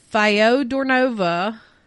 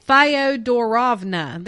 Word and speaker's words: Fyodorovna: For her part, the shy Alex, who took the name, so she changed her name too, Alexandra Fyodorovna, I'm not saying that right, Fyodorovna, there we go,